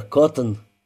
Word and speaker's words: Коттон: «Ну а пока, Коттон...» Бирнс запнулся. «Я правильно говорю, Коттон?» «Да, сэр, Коттон».